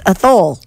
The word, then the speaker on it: Athol: A-T-H-O-L. – That's Athole.